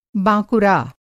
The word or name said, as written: बाँकुरा